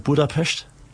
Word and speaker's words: Budapest: Queen war tatsächlich in Budapest.